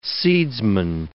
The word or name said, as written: seedsman